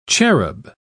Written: cherub

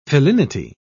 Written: felinity

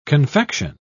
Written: confection

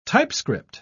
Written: typescript

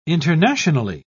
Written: internationally

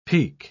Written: pique

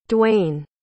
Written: Dwyane

